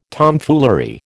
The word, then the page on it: Tomfoolery: American English